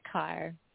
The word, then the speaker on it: Kaur: Sangeeta Kaur. – Kaur. Okay. So just like the car, like you're driving a car.